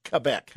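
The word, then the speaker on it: Québec: Not Quebec City, properly pronounced Quebec.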